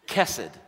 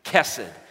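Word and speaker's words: chesed: Here's the Hebrew word, but it is pronounced kesed. Say it with me again like we did last week, kesed.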